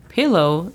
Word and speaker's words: pillow: La pronunciación fonética es J-A-H-L. – Hall. – Muy bien. La segunda palabra es pillow. Pillow significa almohada. La pronunciación fonética es P-I-L-O-U.